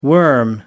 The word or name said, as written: worm